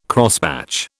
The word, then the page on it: Crosspatch: British English